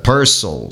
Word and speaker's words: Purcell: Purcell seria a pronúncia correta,